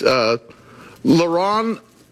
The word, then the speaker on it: leronlimab: It's leronlimab. Is that what it's saying? Did I say that correctly? – Very good.